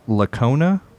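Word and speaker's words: Licona: Mike Lacona, did I say that right, I hope? – Yep, that's right.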